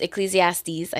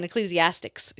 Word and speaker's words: Ecclesiastes: Because she was saying Ecclesiastics. Ecclesiastics. Ecclesiastics.